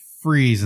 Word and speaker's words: Fries: And I was like, they keep saying Freeze and spelling it Fries. So I had to look it up, and it's accredited as spelt like Fries. Apparently that's how you pronounce Freeze.